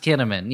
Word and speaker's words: Kinnaman: It's actually pronounced Kinnaman, yeah. Every time you say like a cinnamon bun, it's really a Kinnaman bun.